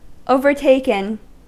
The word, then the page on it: overtaken: American English; /ˈovəɹteɪkən/